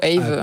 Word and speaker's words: Aave: Oui, on peut dire euh, AAVE ou AVE.